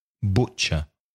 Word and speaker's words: butcher: Again, careful with the pronunciation. The vowel sound is u, the first vowel sound, u. Butcher.